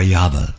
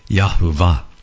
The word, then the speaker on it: Yahweh: That is YHVH, Yod-Heh-Vav-Heh, or Yahweh, or others pronounce it different ways. They might even say Yahweh or Yahuwah.